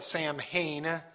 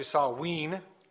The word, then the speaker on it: Samhain: Many people pronounce that Samhain. It's actually Samhain.